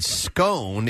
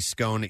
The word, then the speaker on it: scone: In February, fans pointed out that when she said scone, it rhymed with gone, which is a classic English pronunciation. So scone. – Aha. Uh-huh. – When Americans say scone, it usually rhymes bone.